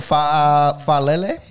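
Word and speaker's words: Faalele: Daniel Falele.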